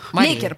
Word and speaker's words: Леккер: Все ли я правильно произношу? – Лейкер, правильно Лейкер.